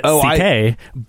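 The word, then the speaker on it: Székely: S-E-A-I-J. – Is that how you say it? – Oh, yeah. You say it C.K., so he shortened it to C.K.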